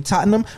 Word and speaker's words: Tottenham: When I look at like Tottenham. Let me say it like proper. You feel me? Tottenham.